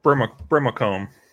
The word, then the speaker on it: Brimacombe: James Brimacombe.